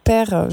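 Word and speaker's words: Pere: Pierre, Père, je n'arrive pas à le prononcer comme vous. Hein. – Non, c'est très bien.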